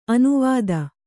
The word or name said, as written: ಅನುವಾದ